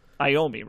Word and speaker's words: Iommi: which is like Yami's. Is it Iommi, right? That's, yeah, how you pronounce it.